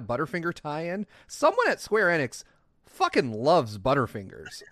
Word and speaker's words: Enix: What is it with Square Enix, or is it Square Enix? Square Enix.